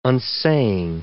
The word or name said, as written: unsaying